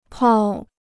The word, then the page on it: 泡: pào